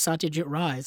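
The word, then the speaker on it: Satyajit Ray: And she pronounced it as Satyajit Rai. So if Audrey Hepburn pronouncing it, I would just go with what she says.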